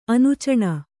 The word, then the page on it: ಅನೂಚಾನ: anūcāna